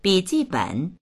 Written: bi3ji4ben3

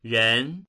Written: ren2

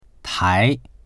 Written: tai2

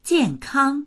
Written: jian4kang1

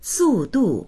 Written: su4du4

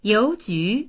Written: you2ju2

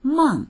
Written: meng4

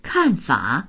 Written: kan4fa3

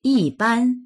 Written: yi4ban1

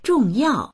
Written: zhong4yao4

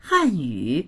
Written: han4yu3

